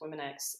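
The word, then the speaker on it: WomenX: But we do spell Women X that way. And sometimes we just pronounce it women, but it is pronounced Women X.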